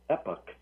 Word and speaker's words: epoch: It seems, I looked it up, Chuck, I was giving you the British pronunciation, so you can say epoch, too.